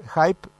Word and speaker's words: Hype: Hype, ¿lo pronuncié bien, Matías? – Impecable.